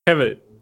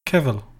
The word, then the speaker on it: Cavill: Wir sollen es aus wie Travel, nur mit C. Cavill. Cavill.